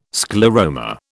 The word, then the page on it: scleroma: British English